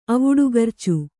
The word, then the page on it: ಅವುಡುಗರ್ಚು: kannada